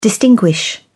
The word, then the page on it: distinguish: British English